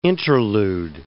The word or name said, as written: interlude